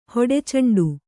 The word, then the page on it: ಹೊಡೆಚೆಂಡು: hoḍe ceṇḍu